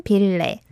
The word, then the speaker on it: 빌레: So, as always, I hope you can pay attention to the continuous sound of 리을 in 빌레, 소원 빌레. – 소원 빌레. – Yes. – 빌레. That 리을 sound is from the 받침, the bottom consonant, through to the next syllable sound as well.